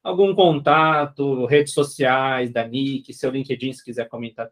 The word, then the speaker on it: niky: É, ele, Nick se escreve N-I-K-Y.